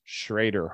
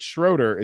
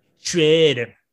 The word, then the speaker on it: Schroeder: There is a building that's spelled Schroeder Hall, but it's pronounced Schrader Hall. So anyone who calls it Schroeder is automatically a noob. – Schrader.